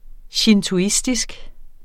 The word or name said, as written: shintoistisk